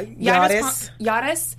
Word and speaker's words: Yairis: Am I saying her name right? Yairis Palmer? Y-R-I-S? – Yeah. – Yairis.